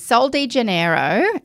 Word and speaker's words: Sol de Janeiro: Sol de Janeiro, not the bum bum cream.